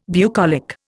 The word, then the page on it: bucolic: Indian